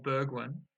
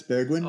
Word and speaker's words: Bergwijn: Dan's seventh round pick of Bergwijn No, I'm not sure if I pronounced that right. Can you maybe correct me on that one? How do we pronounce that one? – Yeah, no, it's Bergwijn.